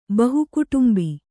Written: ಬಹುಕುಟುಂಬಿ

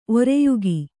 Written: ಒರೆಯುಗಿ

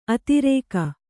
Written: ಅದುರುಗ

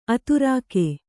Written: ಅದುರುಗ

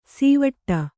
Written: ಸೀವಟ್ಟ

